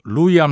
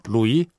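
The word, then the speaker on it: Louis: 근데 철자가 L O U I S거든요. 음. 이걸 저 프랑스식으로 발음하는 거죠. 루이.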